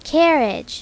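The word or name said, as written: carriage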